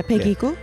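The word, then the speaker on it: Peggy Gou: So you also said something about Peggy Gu. Is that how you say it, Peggy Gu? – Yeah, Peggy Gu.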